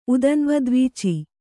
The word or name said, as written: ಉದನ್ವದ್ವೀಚಿ